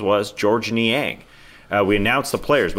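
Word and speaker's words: Georges: Niang, you don't pronounce the S. It's not George's.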